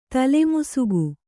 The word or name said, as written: ತಲೆಮುಸುಗು